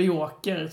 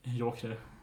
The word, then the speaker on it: euchre: stavas E-U-C-H-R-E. Vilket ska uttalas någonting joker.